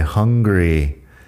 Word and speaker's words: hungry: La pronunciación ahí entre hungry y angry es importante diferenciarlo. Hungry tiene la H aspirada y una vocal más corta. Angry tiene una vocal algo más larga y bueno, sin la H, angry, de enfadado.